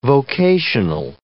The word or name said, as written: vocational